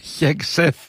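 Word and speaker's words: Hegseth: Ναι, και ο Χέξεφ, για να το προφέρω πλέον σωστά, όπως με διορθώνουν, και ο Ρούμπιο, όλοι έχουν τα μούτρα κάτω κάπως.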